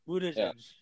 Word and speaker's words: Buttigieg: Like, Buttigieg or something like that? Okay. I'm assuming that they don't support. And then... – Buttigieg.